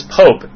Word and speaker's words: POAP: At my school, we have an organization known as POPE, P-O-A-P, pronounced Pope, a.k.a.